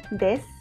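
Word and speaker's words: des (です): La palabra des, que se escribe el desu, es el verbo cero estar.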